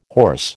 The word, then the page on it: horse: American English